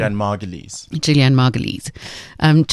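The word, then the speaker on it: Julianna Margulies: Julianne Margulies.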